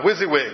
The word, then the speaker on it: WYSIWYG: It's pronounced WYSIWYG, in case you've never heard that word, WYSIWYG.